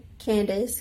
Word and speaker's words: Kandace: Yes, on Instagram, it's my name is underscore Kandace, K A N D A C E. If you don't spell it right, you won't find me.